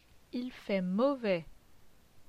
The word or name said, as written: Il fait mauvais